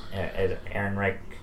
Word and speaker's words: Ehrenreich: Ehrenreich. – Alden Ehrenreich. – Ehrenreich.